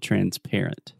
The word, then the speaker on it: transparent: Yeah, the stress is on the middle syllable, transparent.